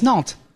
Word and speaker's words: Nantes: There is no R. It's Nantes.